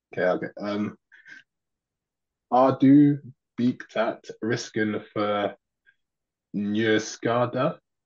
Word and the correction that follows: beaktat: The third word is beaktat, but that's not quite Beaktat.